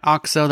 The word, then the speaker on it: OXO: OXO? – Yeah, OXO, that's how I say it. – It's spelled O-X-O.